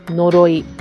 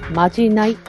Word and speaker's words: 呪い: その呪に、ひらがなのイをつけると二通りの読み方があるそうです。一つは呪いです。そしてもう一つはまじないと読むそうです。